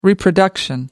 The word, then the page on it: reproduction: American English